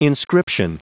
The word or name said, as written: inscription